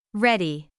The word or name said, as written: ready